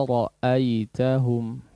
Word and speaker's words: رَأَيْتَهُمْ: Wa idza ra'aitahum ya, khususnya di sini ya konteksnya adalah coba perhatikan hamzahnya. Pengucapan hamzah ini sering terlalaikan ya, cukup sering terlalaikan. Jadi hamzahnya tidak betul-betul diucapkan dari makhrajnya ya, hanya suara mad ya, bisa dikatakan suara jauf saja itu ya, bukan suara mad. Contoh seperti apa? Wa idza ra'aitahum wa idza ra'aitahum, enggak ada hamzahnya itu ya, itu dikeluarkan dari jauf ya, bukan dari bukan dari pangkal tenggorokan ya.